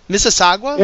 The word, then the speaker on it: Mississauga: Okay, Nicholson, who I just talked about, he's from Mississauga. I said it right this time, huh? Yeah. Mississauga? – Yeah. – It's like Mississippi.